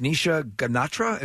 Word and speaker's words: Nisha Ganatra: And you also have, I believe her name is pronounced Nisha Ganatra. Is that how it's pronounced? – Yes.